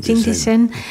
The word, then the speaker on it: Jingdezhen: Dit jaar nog bent u naar China gereisd om daar aan de International Academy of Ceramics in Jingdezhen, zeg ik het goed? – Jingdezhen.